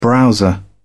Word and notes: browser: British English

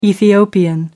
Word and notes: ethiopian: American English

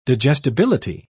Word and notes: digestibility: British English

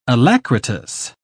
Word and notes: alacritous: British English

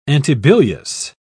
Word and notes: antibilious: British English

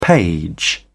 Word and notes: page: British English

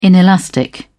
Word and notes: inelastic: British English

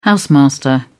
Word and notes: housemaster: British English

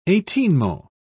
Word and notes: eighteenmo: British English

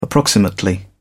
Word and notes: approximately: British English

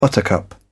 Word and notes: buttercup: British English

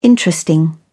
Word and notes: interesting: British English

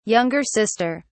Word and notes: younger sister: British English

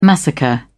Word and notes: massacre: British English

